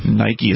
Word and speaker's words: Nike: Nike is how you pronounce that, I believe.